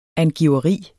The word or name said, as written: angiveri